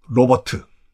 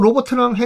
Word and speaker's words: 로버트: 이왕 로버트 태권브 얘기 나온 김에 발음이 달라요. 이거 아주 말장난하시는 분들이 계신데 사람 이름 로버트는 로버트예요. 이름 잘 액센트. 그리고 로버트 태권브 할때 로버트는 로봇. 발음이 달라요. 로버트 사람 로봇 로버트.